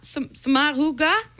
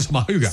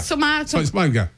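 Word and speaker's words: Sommaruga: Alors, Simonetta Soma, Somaruga. – Non, c'est Somaruga. – Somaruga. Ah, oui. Somaruga.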